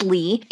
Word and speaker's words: Lee: My name is Lee, and it's L E E spelled like a boy.